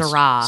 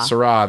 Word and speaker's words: Syrah: Combining a roasty imperial stout with jammy Syrah wine notes. I don't drink wine. Is that how that's pronounced? – Syrah. – Syrah, there we go.